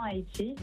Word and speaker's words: Ayiti: Entonces es A-Y-I-T-I, pero se dice igual, se pronuncia igual.